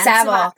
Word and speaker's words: Savile: Again, Savile.